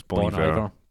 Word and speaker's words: Bon Iver: Just going to correct your pronunciation. It's Bon Iver.